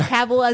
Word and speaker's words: Cavill: And yes, that's Cavill as in rhymes with travel, as he said in one of his videos.